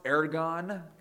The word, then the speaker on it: ergon: Well, the word work is pronounced ergon, and it's from where we get the word energy.